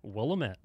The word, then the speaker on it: Willamette: The snobbery out here in Napa Valley and Calistoga, I'm telling you, how do you say it? The Willamette. The Willamette. – Willamette.